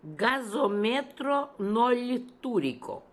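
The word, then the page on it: Gasometronoltooriko: Russian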